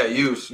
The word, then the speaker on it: Youss: Youss. Youss is cool, man.